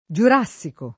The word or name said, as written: giurassico